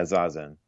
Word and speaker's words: Zazen: And just to get some vocab down, and tell me how to pronounce this. Zazen? – Yeah, Zazen.